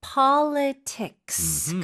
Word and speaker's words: Politics: Politics. 끝까지 S도 발음해 주셔야 돼요. Politics. – Politics. 왜냐하면 S가 없으면 명사가 되지 않죠. – Yeah. – 그래서 S 포함이 돼야 되고 PO 쪽에 강세를 꽝 찍어주시고요.